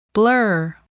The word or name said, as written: blur